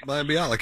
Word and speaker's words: Mayim Bialik: Mayim Bialik, yeah.